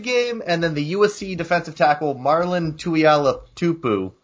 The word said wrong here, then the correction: Tuipulotu: Marlon Tuipulotu.